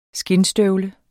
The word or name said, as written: skindstøvle